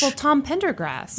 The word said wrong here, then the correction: Pendergast: I totally mispronounced that last Pendergast.